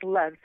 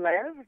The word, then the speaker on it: Lev: And it's pronounced Leave or Lev or? – It's pronounced Lev. So Lev. – It's pronounced Abigail and Lev.